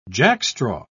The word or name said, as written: jackstraw